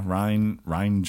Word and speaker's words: Rian: Yeah, Rian Johnson, right? Did I say that right? – Mm-hmm.